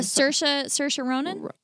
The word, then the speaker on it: Saoirse Ronan: Saoirse Ronan? – Yeah.